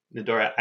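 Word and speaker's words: n'dorë: Nadore, I can't speak Albanian, so I apologize for Actually, your pronunciation was perfect.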